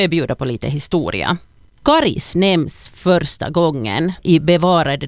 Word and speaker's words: Karis: Rekommendationen om långt A, Karis, har funnits sedan början av 1960-talet, och för det så fanns det faktiskt inte uttalsrekommendationer överhuvudtaget.